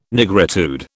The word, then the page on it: nigritude: British English